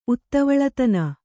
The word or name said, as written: ಉತ್ತವಳತನ